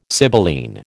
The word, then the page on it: sibylline: American English